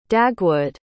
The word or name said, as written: dagwood